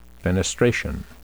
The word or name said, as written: fenestration